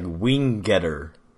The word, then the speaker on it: Wingenter: They pronounced it like there's two G's there, so it's like Wingetter.